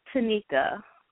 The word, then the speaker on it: Tanyka: Tell me right. How is it? – It's Tanika. – Tanika. I'm sorry. It's T-A-N-Y-K-A. – Y-K-A, yes.